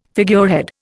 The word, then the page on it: figurehead: Indian